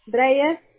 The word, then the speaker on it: Libni: Reyes. – Reyes, perfecto. Y tu primer nombre se escribe L-I-B-N-I, sí.